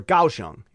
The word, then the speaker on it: Kaohsiung: They go to the big city, to Taipei and to Kaohsiung, or Kaohsiung, it's a K. But it's pronounced like a G.